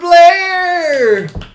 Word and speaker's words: Blair: I'm Spookster Blair! Scared. Thank you for that applause. Just for the name. – Your last name rhymes with scare.